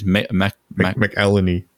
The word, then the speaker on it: McElhenney: McElhenney.